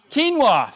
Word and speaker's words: quinoa: Quinoa? See? Quinoa, thank you. That's it.